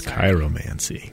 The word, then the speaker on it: chiromancy: And if I'm mispronouncing that, it's chiromancy. – You know, that sounds better. CH, I'm just going to say a ch. – Yeah, a chiropractor. – Oh. – That's where chiro.